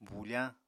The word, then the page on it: bufañ: Apatani